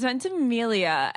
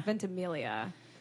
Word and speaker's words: Ventimiglia: Then they're like, it's Ventimiglia. And I go, oh my God. Like I played it off. I was like, oh my God, I've been saying my future husband's last name wrong for the past, year.